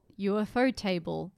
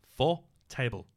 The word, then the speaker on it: Ufotable: Ufotable, UFO table. How do I pronounce it again? – Ufotable.